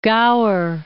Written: Gower

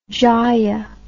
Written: jaya

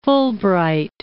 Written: Fulbright

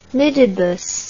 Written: midibus

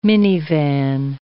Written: minivan

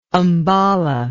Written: ambala

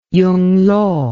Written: yunglo

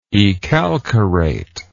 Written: ecalcarate

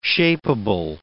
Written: shapable

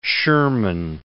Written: Sherman

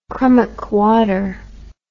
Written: crummockwater

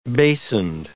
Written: basined

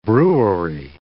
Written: brewery

